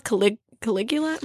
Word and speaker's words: Caligula: So she's, like, known as the female Caligula.